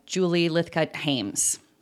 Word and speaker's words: Julie Lythcott-Haims: How to Raise an Adult by Julie Lythcott-Haims.